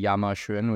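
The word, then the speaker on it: Yamashun (亚马逊): Amazon was dealing with a tough branding problem because in China, the pronunciation for Amazon is actually Yamashun, which translates to pile of horse shit.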